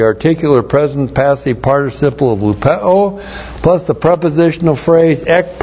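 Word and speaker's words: lupeo (λυπέω): The articular participle of lupeo L-U-P-E long O lupeo.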